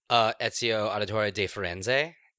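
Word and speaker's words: Ezio Auditore da Firenze: Ezio Auditore de Firenze.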